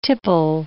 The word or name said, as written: tipple